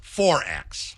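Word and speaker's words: XXXX: Quadruple X in Australia. The beer is pronounced 4X.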